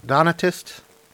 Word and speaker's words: Donatist: And then we'll move through, you know, there was the, what was the D-O-N-T-A-I-S-T, those, how you say it, I can't remember, blank Donatist. Donatist? – Yes.